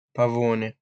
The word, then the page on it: Pavone: /pa.'vo.ne/